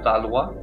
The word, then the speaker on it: Valois: He apparently had numerous identities, possibly being a member of the former royal family, the Valois. Is that correct, sir? Pronunciation? – Yes, that's correct.